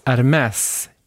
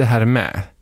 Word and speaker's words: Hermès: Han menade, hallå, väskorna uttalas Hermès, inte Hermé.